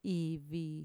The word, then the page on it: ivi: Mixtec